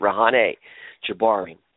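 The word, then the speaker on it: Reyhaneh Jabbari: As you know, I'm writing a book about Rehana Jabari, Rehana. It's really the correct way to say it, Rehana Jabari.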